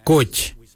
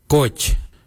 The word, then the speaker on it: goodj: Garli is spelled G-I-I-L-Y-I, garli. For the traditional stone axe, we say goj, goj. Goj is spelled G-O-O-D-J, goj.